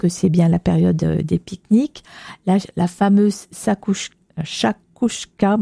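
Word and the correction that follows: chakchouka: je ne sais jamais le dire, c'est très bon, mais difficile à prononcer. – Chachouka, non? – Oui, oui. – C'est chachouka. – Oui, moi j'ai... – Non, c'est ça, chachouka.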